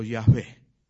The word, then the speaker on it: Yahvé: El segundo nombre que tiene Dios en la Biblia es Jehová o Yahvé, que también se puede pronunciar así.